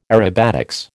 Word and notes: aerobatics: American English

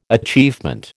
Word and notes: achievement: American English

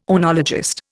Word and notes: oenologist: Indian

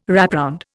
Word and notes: wrapround: Indian

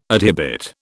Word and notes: adhibit: British English

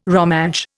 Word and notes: Romansh: Indian

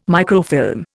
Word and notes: microfilm: Indian

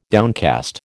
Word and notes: downcast: American English

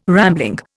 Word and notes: rambling: Indian

